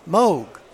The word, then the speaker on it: Moog: Moog rhymes with Vogue.